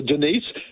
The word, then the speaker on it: Denis: I've got one for you, Dennis or Denise. Play yourself the Debbie Harry song, Dennis or Denise. It's spelled D-I-N-I-S. – Yeah, Denis. – Yeah. – Which is the French pronunciation.